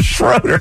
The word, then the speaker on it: Schroeder: You prefer Schroeder? The guy's from Germany. – I understand. – It's pronounced Schroeder. – I understand.